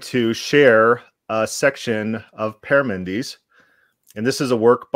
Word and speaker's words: Parmenides: Parmenides, and that's the correct way to say it, the way you said it last night.